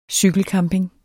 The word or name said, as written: cykelcamping